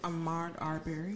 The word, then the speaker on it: Ahmaud Arbery: Ahmaud Arbery.